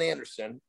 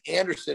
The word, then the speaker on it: Andersen: But I want people go to my Instagram account, which is the John Andersen, at the John Andersen. But keep in mind, my name is spelled a little goofy. I'm Nordic. So J-O-N, which is not the Nordic part. And Andersen is A-N-D-E-R-S-E-N.